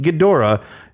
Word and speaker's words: Ghidorah: Godzilla must battle its way from one ring field to another and finally defeat Ghidorah.